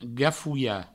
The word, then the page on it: gafolhar: gafouia